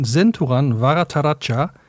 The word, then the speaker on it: Senthuran Varatharaja: Und ich habe von Senturan Varataracha Rot, Klammer auf, Hunger, Klammer zu mitgebracht.